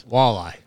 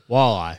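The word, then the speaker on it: Waialae: The Wailea Golf Club. – Close. It's dang it. – Walleye. – I have to fire my secretary. She keeps giving me the pronunciations. – Walleye. – Like the fish. – Similar to the fish. You just kind of have to say it like you're a little tipsy.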